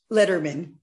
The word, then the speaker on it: Lederman: Lederman. – Lederman, as in David, but without the extra D.